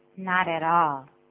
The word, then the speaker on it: not at all: 实际上，人家不这么说的，所以连在一起怎么说呢？Not at all， not at all，请注意 t 会变成 d 的声音。